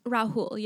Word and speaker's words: Rahul: Rahul, yeah. It's like Rahul with an H.